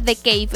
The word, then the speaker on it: The Cave: Así se escribe, se pronuncia The Cave en inglés, pero se escribe The Cave.